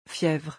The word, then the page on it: fievre: French